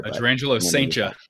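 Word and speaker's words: Jurrangelo Cijntje: Gerangelo Sainja. – Sainja, there we go. Yes, I wrote it down actually last night. Gerangelo Sainja is 5'11", and yes, he is a switch pitcher.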